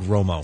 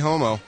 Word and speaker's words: Romo: You mispronounced his last name. You said Romo. – Oh, I'm sorry. Tony Romo. – That's right. There you go.